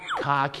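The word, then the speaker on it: khaki: แต่ภาษาอังกฤษก็จะออกเสียงว่าคาขีนั่นเองครับใช่คล้ายๆกับคาขีนั่นแหละฮะ so คาขีก็คือกากีครับ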